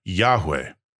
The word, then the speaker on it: Yahweh: However, God's personal name is a different word, pronounced something like Yahweh.